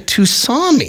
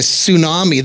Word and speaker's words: tsunami: But he was calling it a Tusami. – He didn't know the T is silent.